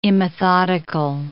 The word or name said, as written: immethodical